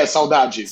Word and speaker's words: saudade: I felt for the first time saudade. Am I saying that right? – Yeah, saudade. – saudade. – Which is a word that only exists in Portuguese.